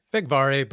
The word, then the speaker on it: Figari: And like I said, we have a fourth person on this podcast, and that is Steve Figari. Did I say that right? – Figari, but you know what? I get it all over the place, yeah.